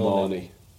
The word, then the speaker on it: Marnie: Ah, the lovely Joe Marnie. – Rhymes with Pakistani if you're from the East End, but there you go.